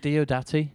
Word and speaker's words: Diodati: Is it Diodati? – That's how I've had it in my head.